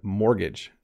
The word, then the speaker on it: mortgage: But mortgage, you don't say the T. Okay? Mortgage.